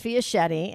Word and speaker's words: Fiaschetti: Well, my last name is Fiaschetti, and that rhymes with spaghetti.